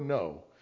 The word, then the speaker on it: know: First off, the word know comes from gnosis. There's a reason why you have a silent K in the front. It's because there's a G in the front of the Greek word, gnosis.